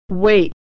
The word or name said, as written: wait